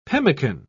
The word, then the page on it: pemican: British English